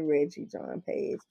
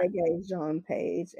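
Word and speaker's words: Regé-Jean Page: And last week, y'all, pardon me, the man's name is Reggae Jean Page. I say Reggie Jean Page. – Oh, okay. – It's actually Reggae, like the music, Reggae. – Oh, I would have thought it was a soft G.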